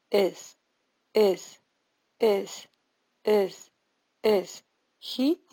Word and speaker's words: is: Acuérdense que el is en inglés es is, pero no se dice is. Es algo entre la E y la I. Es una nueva vocal que no se usa en español.